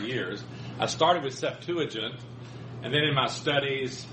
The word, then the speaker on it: Septuagint: This is called the Biblical Hebraic Septuagintia, which is really just a form of that Rabbinic Bible, just an updating of it.